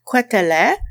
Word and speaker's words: Quételet: Nosso influencer hoje é Adolphe Quetelet, e sim, a pronúncia é assim em francês.